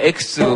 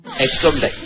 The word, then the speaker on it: 엑소: 이거는 뭐, 예, 중이 아들, 엑소 바라기 딸, 엑소 발음 똑바로 해야 됩니다. 이거 잘못해서 뭐 엑스, 뭐 엑사 이렇게 했다가는 아주 게시판에서 아주 끝장이 날 수 있어요. 엑소입니다. E, X, O. 엑소.